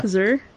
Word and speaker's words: HDURR: H-D-U-R-R. – Yeah. – Huzur.